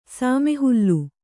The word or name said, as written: ಸಾಮೆಹುಲ್ಲು